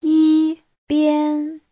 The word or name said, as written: yi1bian1